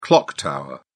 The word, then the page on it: clock tower: British English